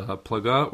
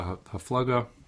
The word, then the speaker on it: Haflaga: and the generation of the HaPlaga, or the HaFlaga, how it's typically pronounced, is like the Dor HaFlaga is the like the Tower of Babel, that generation after Noah, they got it.